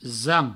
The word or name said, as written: zan